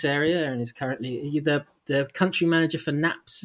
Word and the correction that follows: Knaap: is that right? – That's right, country manager for Knapp Bikes.